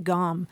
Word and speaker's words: gaum: But usually it's spelled G-A-U-M. We should say that, gom. – G-A-U-M.